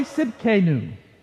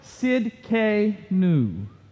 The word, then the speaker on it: Tsidkenu: I mean, they need to buy a vowel between the T and the S. I mean, come on. It's Yahweh Tsidkenu. Yahweh Tsidkenu.